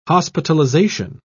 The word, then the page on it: hospitalisation: British English